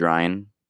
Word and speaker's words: Ryan: My name is Ryan, R-Y-A-N, so not so interesting, right?